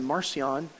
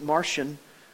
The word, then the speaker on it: Marcion: And so as they gathered together, and the New Testament even came under attack by a fellow named, I pronounce his name Marcion, others pronounce it Martian.